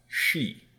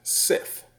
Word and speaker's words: Sidhe: So the word is spelled S-I-D-H-E. – Yeah. – And as far as I know, that word is pronounced she. – She? – She. – Wow. – But I have heard people pronounce it Sith. – What? I would guess side.